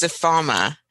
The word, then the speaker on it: Ifeoma: My first name is pronounced Ifeoma, and Ife is just the if part of that. But it's tricky because there's an E there that people want to make an Ifeoma, but it's as if there is no E.